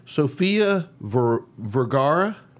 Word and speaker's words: Sofia Vergara: One of our favorite actresses, I know she's one of mine, and I'm sure she's one of yours, Sofia Vergara.